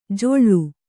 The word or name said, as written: ಜೊಳ್ಳು